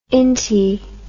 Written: inty

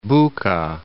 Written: Buka

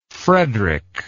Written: fredric

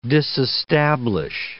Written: disestablish

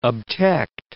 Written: obtect

